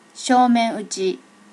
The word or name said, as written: shomen uchi